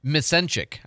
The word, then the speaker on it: Misencic: That was Brittany Misnick, who I've not met her yet. Misenchik, I think is how you say her last name. M-I-S-E-N-C-I-C, new with the PNJ.